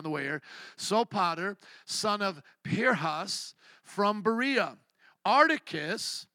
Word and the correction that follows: Aristarchus: Art, or oh, excuse me, R. Aristarchus, there we go, Aristarchus and Secundus.